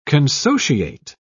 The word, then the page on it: consociate: British English